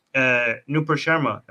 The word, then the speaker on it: Nupur Sharma: But it's also embarrassing for Nupur Sharma.